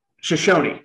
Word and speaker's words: Shoshone: Is it Shoshone? Is that how you pronounce that? – Does it start with a CH? – Shoshone. Okay. See, I didn't know if you pronounce the E or not.